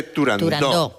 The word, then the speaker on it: Turandot: Turandot no, se dice Turandot, que es... – Como lo pronunciaba, Puccini. Puccini y como lo pronunciaba Toscanini.